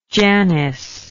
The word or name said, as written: janice